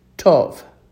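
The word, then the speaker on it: tov (טוב): Notice that tov and boker both sound exactly the same as far as the long O sound.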